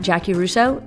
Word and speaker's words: Jaci Russo: If you are looking for a specific topic, you can always hit me on Twitter at Jackie Russo, and it's spelled weird, J-A-C-I-R-U-S-S-O.